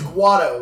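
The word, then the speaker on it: Watto: I don't think that's how he pronounces his name. – Just realized something. a racial slur for his name. It basically is. – Anywho, his name's, no, it's Guado, right? – Yeah, Guado.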